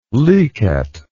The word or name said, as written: licet